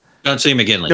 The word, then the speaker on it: McGinley: Or is, am I saying the name right? Is it John C. Riley? Yeah, John C. McGinley. – Yeah, McGinley. Sorry, yeah, John C.